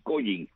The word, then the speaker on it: Collins: Inclusive al machete se le llevó a llamar así Collins, como se escribe, ¿no? Aunque realmente la pronunciación sería Collins en inglés, porque se producía en Collinsville, Connecticut, acá en Estados Unidos.